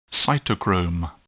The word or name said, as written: cytochrome